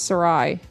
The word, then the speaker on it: Sarai: S-A-R-A-I? – Mm-hmm. – That's Sarai. – It's Sarai.